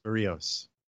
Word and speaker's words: Berríos: Is that the right pronunciation? – Yes, Berríos.